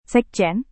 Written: sekjen

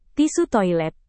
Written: tisu toilet

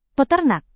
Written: peternak

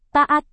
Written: taat

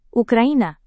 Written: ukraina

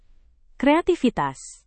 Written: kreativitas

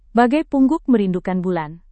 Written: bagai pungguk merindukan bulan

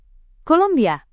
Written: kolombia

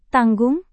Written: tanggung

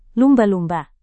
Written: lumba-lumba

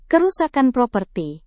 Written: kerusakan properti

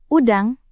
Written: udang

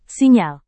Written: sinyal

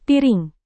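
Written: pisau

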